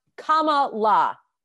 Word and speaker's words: Kamala: My palate cleanser is Madame Vice President, Kamala Harris, pronounced Kamala. – Yeah, get it's it not that right. Hard. Yeah, come on. – There's great shirts on Amazon that literally have a comma and la after it.